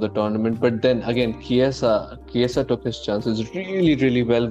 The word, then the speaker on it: Chiesa: I don't know if I'm pronouncing his name wrong. Is it Chiesa? – Yes, I believe he's the best.